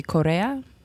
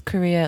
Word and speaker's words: Correa: and their family insisted on pronouncing it properly, which would be Correa, because it's Mexican-American originally. And I just thought, man, it's so much trouble. It's just everyone says Korea. It looks like Korea.